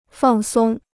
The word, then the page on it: 放松: fàng sōng